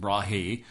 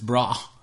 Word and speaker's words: Brahe: Brahe. It looks like I always used to pronounce it Brahe. – Yeah, I did too. – But it's Brahe. It's Danish.